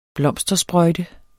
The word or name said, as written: blomstersprøjte